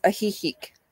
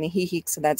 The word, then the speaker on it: Ajijic: The next one that we're looking to go to is Ajijic. And you actually, you spell that A-J-I-J-I-C.